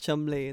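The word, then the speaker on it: Cholmondeley: Is Chumley the one that's spelled Charmansley? – Cholmondley, yeah.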